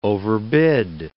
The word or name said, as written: overbid